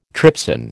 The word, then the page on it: trypsin: American English